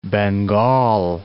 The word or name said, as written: Bengal